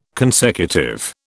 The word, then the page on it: consecutive: British English